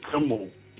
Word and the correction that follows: Kumble: Kimball. I can't pronounce his name properly. – Kumble. – Kumble. – Kumble. Yeah. Arnel Kumble.